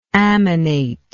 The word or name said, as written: ammonation